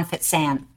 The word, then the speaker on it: Fecan: Uh, the correct pronunciation of his name is Ivan Fecan.